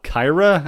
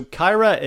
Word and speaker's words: Cyhra: The band's called, I think it's called Kyra, and it's spelled C-Y-H-R-A. – Okay. – So Kyra is a sort of super group,